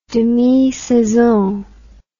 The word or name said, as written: demisaison